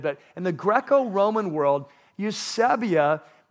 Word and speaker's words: Eusebia: So the word in Greek is the word Eusebia, and it's spelled like this, E-U-S-E-B-I-A, Eusebia.